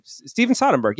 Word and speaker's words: Steven Soderbergh: Steven Soderbergh. Yeah, I'm saying it right.